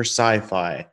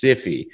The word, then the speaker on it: Sify: Not to be mistaken for Sci-Fi. I made that mistake twice and she didn't correct me both times, but it's Sify. So yeah. If anyone out there is wondering, that's how it's pronounced.